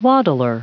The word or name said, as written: waddle